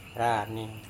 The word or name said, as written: rane